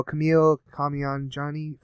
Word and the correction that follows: Kumail Nanjiani: from the Kumail Nanjiani. Yeah. – So I'm very sorry. I mispronounced it, but I knew I would.